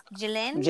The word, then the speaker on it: Ghislaine: I wanted to make sure I said her name right because I thought it was Ghislaine or something, but it's Ghislaine Maxwell.